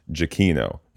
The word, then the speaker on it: Giacchino: my name is actually pronounced Giacchino, not Gia as it looks.